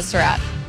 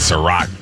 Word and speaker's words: Surratt: What did you learn from Chaz Surratt? – You said it right. We got to fix that. I've been saying Surratt. – Me too. – For a couple years now. Surratt.